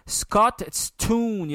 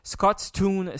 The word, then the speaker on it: Scotstoun: C'est dur à prononcer en fait, Scotstoun, il y a comme un TST, Scotstoun Stadium.